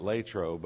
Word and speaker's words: Latrobe: Now, they actually pronounce it Latrobe, but I just can't make myself do it without a lot of practice. But for those of you who aren't golf fans, there's another reason Latrobe is famous.